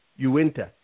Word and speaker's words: Uinta: Your BOCES, your ESA – comprises of Sunrise School in Fremont County, Hayden Peak Academy in Uinta. I hope I'm pronouncing that right, County. Uinta. Just like it says.